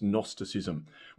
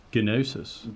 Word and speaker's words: Gnosticism: What's Gnosticism? Which, by the way, spelt with a G. – Gnosis.